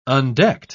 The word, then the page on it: undecked: British English